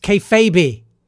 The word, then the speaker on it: kayfabe: Pablo says, it's pronounced kayfabe.